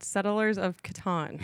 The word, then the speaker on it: Settlers of Catan: I was told by an inside source that you play a game called Settlers of Catan.